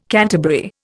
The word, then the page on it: canterbury: Indian